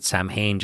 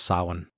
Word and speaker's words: Samhain: And I think most people pronounce it Samhain just because nobody had ever seen or, you know, they never even heard anybody ever pronounce it. But yeah, it's actually pronounced Samhain.